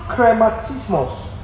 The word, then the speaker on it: chrematismos: That word, answer of God, is a word in the Greek, krematismos. Krematismos. I'll spell that for you. C-H-R-E-M. A-T-I-S-M-O-S. I'll spell it again. C-H-R-E-M-A-T-I-S-M-O-S. Krematismos. It's another word, krematizo, in the Greek. C-H-R-E-M-A-T-I-Z-O.